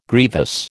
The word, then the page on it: grievous: American English